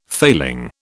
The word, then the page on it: failing: British English